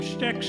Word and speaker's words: Styx: And now we'd like to do a little number here by a great group of musicians from the 80s named Styx. It's not spelled like drumsticks, that's S-T-Y-X, like the river Styx.